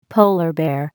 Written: polar bear

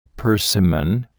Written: persimmon

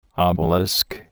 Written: obelisk

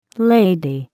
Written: lady

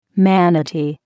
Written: manatee

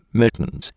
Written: mittens

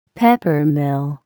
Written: peppermill

